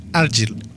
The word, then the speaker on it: Argyll: Argyll.